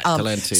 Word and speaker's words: Talenti: So is it Talenti? Am I saying it right? – Yeah, Talenti.